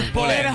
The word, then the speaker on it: Xupò: Sciupò. – Sicuramente sì. – Allora, un po' lombardo. – Sciupò. – Si scrive Xupò. Xupò. Ma è Sciupò.